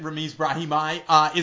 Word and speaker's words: Ramiz Brahimi: Why do we like him, and how close did I get on pronouncing his name correctly? – First of all, you nailed it. Ramiz Brahimi is my pick for underdog.